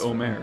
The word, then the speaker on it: Omer: I said Omar Asik instead of Omer Asik.